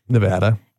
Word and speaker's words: Nevada: Nevada.